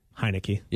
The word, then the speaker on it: Heinicke: Heinicke. Yes. But it's a Heine with a key.